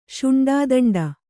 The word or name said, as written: ಶುಂಡಾದಂಡ